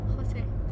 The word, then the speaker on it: José: Alors déjà vous prononcez mal le S. – Non, bah José. Personne nous a appris espagnol. – José.